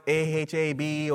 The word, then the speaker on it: Ahab: It's spelled A H A B or A H E B, and I think it's supposed to be pronounced Ahab, but it means to have affection for.